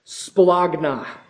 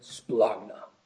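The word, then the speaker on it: Splagna (σπλάγχνα): Splagna. It's a splagna. Yeah, that's really how it's pronounced.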